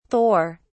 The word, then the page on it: thor: British English